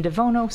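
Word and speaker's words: Devuono: So the whole thing is Christine Devono, so it's C-H-R-I-S-T-I-N-E. D-E-V, as in Victor, U-O-N-O.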